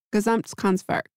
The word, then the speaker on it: Gesamtkunstwerk: So ceramics, fashion, furniture, architecture, and graphic design all converged into, I am going to attempt to say this properly, Gesamtkunstwerk.